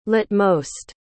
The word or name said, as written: latemost